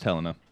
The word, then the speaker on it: Helena: It's pronounced Helena.